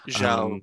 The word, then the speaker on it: Zhao: But Eternals, the director of the Eternals, Chloe Zhao, or Chloe Zhao, I think it's actually pronounced. – Zhao would be, yeah.